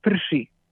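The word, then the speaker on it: Prší: I po której to audycji, w której ta gra się pojawiła, otrzymałem informację, że źle wymówiłem nazwę tej gry. Gra nazywa się Prshi. No, tak się ją wymawia i była to gra karciana, podobna do Uno, tak gdzie było Mau i Mau Mau.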